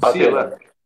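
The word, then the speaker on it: Pathelin: A farsa do advogado Paterlan. É assim que Patelan. fala? Que pronuncia? Patelan. – Patelan, ah, então.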